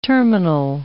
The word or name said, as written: terminal